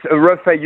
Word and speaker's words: Reffet: It's Reffet. You don't pronounce the T.